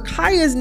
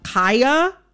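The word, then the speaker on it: Khia: I am going to, in this conversation, pronounce Kaya, the rapper, with the long I. Kaya. okay? My neck, my back, mm-hmm. for those who need a refresher. Kaya, okay? Mm-hmm. The Kia Asylum is a mispronunciation of her name.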